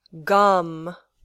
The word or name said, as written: gum